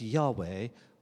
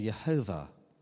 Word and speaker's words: Yahweh: it's the four letters Y-H-W-H, which we pronounce as Yahweh or Jehovah.